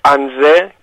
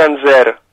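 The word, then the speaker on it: Angers: Εδώ σου κάνουν παρατήρηση, ναι, ότι διαβάζεται Ανζέ και όχι Ανζέρ.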